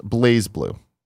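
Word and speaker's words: BlazBlue: It's pronounced Blaze Blue, says TG Burt.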